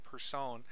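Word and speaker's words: Persson: Notch, Marcus Notch Person, or I think that's how you say his name, Person.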